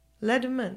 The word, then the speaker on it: Le Domaine: Le Domaine.